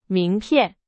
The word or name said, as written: 名片